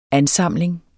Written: ANSAMLINGER

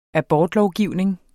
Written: abortlovgivning

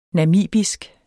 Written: namibisk

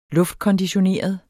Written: luftkonditioneret

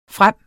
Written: frem

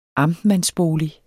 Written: amtmandsbolig

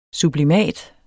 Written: sublimat

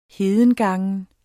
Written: hedengangen

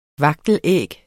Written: vagtelæg

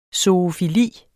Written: zoofili

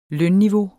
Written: lønniveau

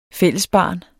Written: fællesbarn

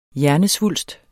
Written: hjernesvulst

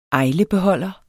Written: ajlebeholder

